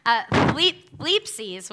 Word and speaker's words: thlipsis: Thlipsis. Ooh. And it's spelled like this, T-H-L-I-P-S-I-S, thlipsis.